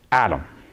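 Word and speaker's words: Adam (אדם): The word Adam in the Hebrew is Adam. It's one of the very few words that we didn't like change the pronunciation when it came into English.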